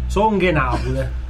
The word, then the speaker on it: Song e Napoli: Come la pronuncia? – Song e Napoli.